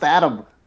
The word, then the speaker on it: Adem: They're like, no, A-D-E-M. I'm like, oh, well, that's ridiculous. – That's Adem. No, it's pronounced Adam.